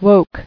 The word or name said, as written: Woke